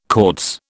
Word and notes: Cortes: British English

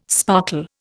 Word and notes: sparkle: Indian

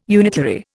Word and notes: unitary: Indian

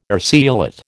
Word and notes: urceolate: American English

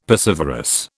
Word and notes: piscivorous: British English

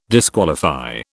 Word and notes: disqualify: British English

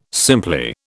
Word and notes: simply: British English

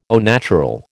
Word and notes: au naturel: American English

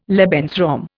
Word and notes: Lebensraum: Indian

